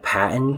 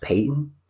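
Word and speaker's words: Paton: It looks like Patton, but I'm told it's pronounced Peyton.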